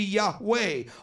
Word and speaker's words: Yahweh: When I say Elijah, Elijah, the name Elijah comes from the name of God, Yahweh, or Yah, but the full pronunciation of the sacred name is believed to be Yahweh, although